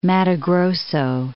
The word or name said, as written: Matto Grosso